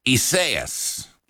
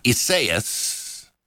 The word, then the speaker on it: Isaias: And the big, big story is Isaias. That's how you say it properly. It's a Spanish-Portuguese word. But yeah, Isaias, and that's the latest tropical storm.